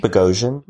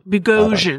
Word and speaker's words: Bogosian: Gil, played by the great, Eric Bogosian. Did I pronounce that correctly? – Bogosian. – Bogosian. Okay. There it is. Yep. That's right.